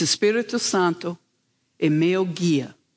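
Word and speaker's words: Espírito Santo é meu guia: was that she taught me how to say the Holy Spirit is my guide. And it's Espírito Santo e meu guia.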